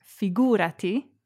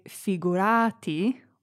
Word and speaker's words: figurati: To really pull off figurati, make sure to put the emphasis on the oo. – Figura. Figurati. – Be careful not to say figurati or figurati. It's figurati.